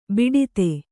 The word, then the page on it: ಬಿಡದಿ: biḍadi